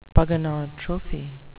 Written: pogonotrophy

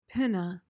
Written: pinna

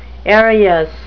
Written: aerious

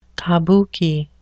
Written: kabuki

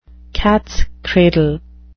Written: cats cradle

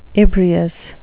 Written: ebrious

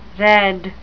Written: redd

